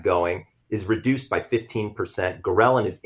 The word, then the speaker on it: ghrelin: There's an increase in a specific hormone called ghrelin, that's spelled G-H-R-E-L-I-N.